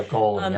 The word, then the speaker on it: golem: In addition to photographing ghosts, which you mentioned, you talk about the legend of the golem and history of automata, if I'm saying that right. – Yeah, golem. Yep.